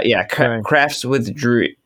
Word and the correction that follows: Dre: Crafts with Dre. Oh my God, I already said it wrong. Sorry, Dre. Wow. It's Crafts with Dre, D-R-E.